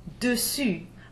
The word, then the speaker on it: dessus: E-S-S-A-Y-E-R, to try. Now here comes the exception to the exceptions. Dessus, above or over, we have it here, par-dessus, D-E-S-S-U-S. Here the E is pronounced as a schwa. The same is true of the word dessous, underneath or below, spelled D-E-S-S-O-U-S.